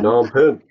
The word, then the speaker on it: Phnom Penh: I think I was going from Siem Reap to Phnom Penh. I think mm-hmm. that's how you say it. Phnom Penh. – Phnom Penh, yep.